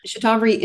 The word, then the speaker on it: Shatavari: It's S H A T A V A R I, Shatavari.